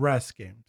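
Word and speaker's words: Rass Games: I'm pretty sure you're pronouncing that wrong. R-A-S-S? Is yes. – That? Russ Games, yeah. – What did I say? – You said Rash. – Like a rash. Okay.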